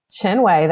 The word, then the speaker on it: Chinwe: Chinwe, that was Chinwe.